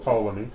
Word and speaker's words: Ptolemy: Okay, then the other one, Ptolemy, I don't know if you pronounce the P exactly, but it's like Ptolemy,